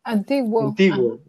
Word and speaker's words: Antiguo: Finca and Antigua Cuscatlán Verde, I pronounced it right, and Antiguo.